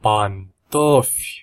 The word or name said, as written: pantofi